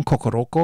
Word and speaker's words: Kokoroko: I always get that name wrong, Kokoroko, because we've got a Maori word, Korokoro, so I have to watch it.